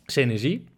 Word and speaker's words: Senesi: En nou, wat past op dezelfde lettergrepen als Bailamos? – Senesi. – Precies, heb je ook de klemtoon goed? – Senesi.